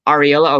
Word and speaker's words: Aurelio: How do you say? A-U-R-E-L-I-O? – Ariello? – Ariello, okay, thank you.